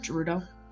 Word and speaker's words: Gerudo: Gerudo.